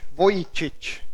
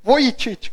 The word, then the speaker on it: Vujicic: Vujicic, creo que se dice su nombre. Vujic, Vujicic. Se escribe Vujicic. Vujicic creo que es.